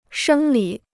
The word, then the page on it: 生理: shēng lǐ